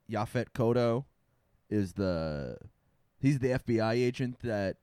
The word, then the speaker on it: Yaphet Kotto: Yaphet Kotto. That's four syllables.